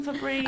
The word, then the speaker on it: Fabrice: His name wasn't Fabrice. – Okay. – I feel bad. – Sorry. – His name was Fabrice. F-A-B-R-I-C-E. Like Fab Rice.